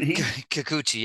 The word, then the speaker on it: Kikuchi: And all of a sudden, and Kikuchi, if I said that right. – Kikuchi, yep.